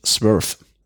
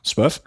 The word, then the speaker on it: Swerve: Durable Cotton Regular Short von der Firma, ich würde es aussprechen, Swerve. Mhm. Wie würdest du es aussprechen? – Auch so? Ja. – Swerve.